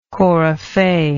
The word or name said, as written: coryphee